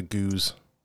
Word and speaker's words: gueuze: I, I call it a gueuze.